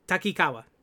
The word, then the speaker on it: Takikawa: Takikawa, that's how you say it. Takikawa.